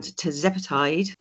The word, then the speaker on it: tirzepatide: So Ozempic, Wegovy, and then the new one, Mounjaro, which is, if I can pronounce this right, tirzepatide. – Tirzepatide, exactly. And tirzepatide is a GLP plus, so to speak.